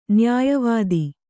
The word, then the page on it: ನ್ಯಾಯವಾದಿ: nyāya vādi